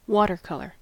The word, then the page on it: watercolor: American English